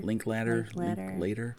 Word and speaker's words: Linklater: It's directed by Richard Linklater. I think that's how you say his name. – Linklater.